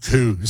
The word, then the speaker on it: coo: You want to know how to pronounce cow in a Scottish accent? It's coo.